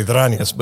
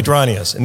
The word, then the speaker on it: Dranias: Well, if you want to be really ethnic, you can say Dranias, but you can call me Dranias, Nick Dranias.